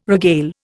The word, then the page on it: regale: Indian